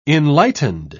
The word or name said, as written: enlightened